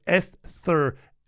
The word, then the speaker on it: Esther: That's Esther, pronounced your TH's boy.